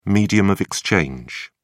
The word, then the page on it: medium of exchange: British English